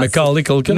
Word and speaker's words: Macaulay Culkin: Macaulay Culkin, Macaulay Culkin.